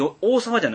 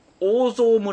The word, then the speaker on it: 大沢: 兵庫県有馬郡大沢と書いてね、うん、これ大沢じゃないんですよ、うん、大蔵村って読むんですよ、